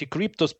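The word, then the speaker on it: Kryptos: Кстати, Cryptos пишется не с буквы C, а с буквы K, то есть K-R-Y PTOS. Вот так.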